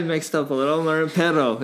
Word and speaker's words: perro: And then perro is pet.